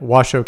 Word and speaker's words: Washoe: You were the you were with Washoe County. I don't know if I'm pronouncing that right. Washoe County School District. – That's correct.